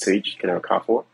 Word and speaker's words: Tage Kene-Okafor: My name is Tage Kenny Okafor.